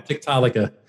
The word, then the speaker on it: Tiktaalika: So it's called Tiktaalika. And thank you. I've been trying to figure out how to pronounce that for a while. Tiktaalika. – Yeah. – I think I was hanging on Tiktaalika, but Tiktaalika. Thank you. – That's easy. The most common way of doing it.